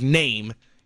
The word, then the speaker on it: Nehm: Follow along at Eric _ Name, NEHM, but it's pronounced Name.